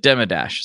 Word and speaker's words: Demidash: I said Demidash, is that right? – You got it.